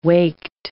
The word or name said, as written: waked